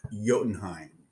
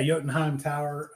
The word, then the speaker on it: Jotunheim: Which I never would have pronounced that way if they hadn't always constantly pronounced it like that way. In the briefing, Amanda Waller pronounced it Jotunheim like 5 or 6 different times, so we all would know exactly how to say it.